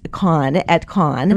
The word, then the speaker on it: Cannes: And I always, I never get the pronunciation. I guess it's Cannes.